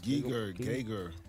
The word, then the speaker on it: Geiger: What does a giga counter measure? – Ebro. – Laura. – Ebro. – That measures I could be saying it wrong. – Megabits, megabytes, gigabytes. – Geiger. – Oh. – That's wrong. – Geiger. – Geiger. – G-E-I-G-E-R. – That's Geiger.